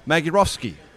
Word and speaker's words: Magierowski: Marek Magierowski.